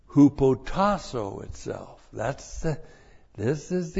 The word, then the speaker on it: hupotasso: Now in verse 7, the key word there is hupotasso. H-U-P-O-T-A-S-S long O. Hupotasso.